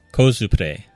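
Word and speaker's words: コスプレ: How would you pronounce it in Japanese? – Kosupure.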